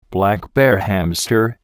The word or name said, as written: black bear hamster